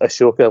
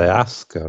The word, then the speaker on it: Ahsoka: it's the A-H-S, which is weird. But also, if I looked at it the other way around, if it being spelled A-S-H, then okay. It should be pronounced Ahsoka, which is weird for me because that's the name of a takeaway near where I live.